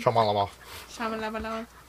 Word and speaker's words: Shyamalan: Shyamalan.